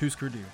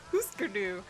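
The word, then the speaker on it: Hüsker Dü: I think it is pronounced Husker Du. – Okay, so it's Husker Du.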